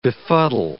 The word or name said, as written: befuddle